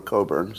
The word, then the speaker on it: Cockburn: Her grandfather was Claude Cockburn, who wrote Beat the Devil, under a pseudonym, so he didn't use his real name, Claude Cockburn, or Coburn, some people would pronounce it.